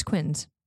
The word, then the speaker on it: Quinze: Q-U-I-N-Z-E. – I think it's Quinz.